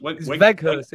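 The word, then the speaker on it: Weghorst: Weghurst. It's pronounced with a V. Weghurst.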